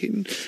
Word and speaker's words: Hickman: Have I said that right? Septic Hickman? – Yep.